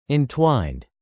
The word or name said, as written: entwined